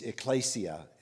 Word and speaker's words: ekklesia: it's ekklesia is really how it's supposed to be pronounced, and it simply means an assembly.